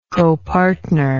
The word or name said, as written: copartner